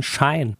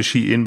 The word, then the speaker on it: Shein: Ich habe immer gedacht, die heißen Shine. – Ja, ich habe es immer schön, so schön Shine ausgesprochen. Ich finde das auch immer noch, noch schöner. Äh, aber im Grunde heißen sie Shein, weil sie von Shein sei kommen.